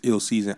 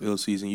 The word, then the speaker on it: ILLSZN: My brand new album, Ill Season, is out on every single major platform, and that's Ill Season, ILLSZN, but it's pronounced Ill Season.